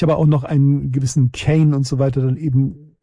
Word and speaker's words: Keane: Von der Frau Monika wird dann nicht Keen ausgesprochen. Nein, wird Kane ausgesprochen. Ich habe extra nochmal nachgehört,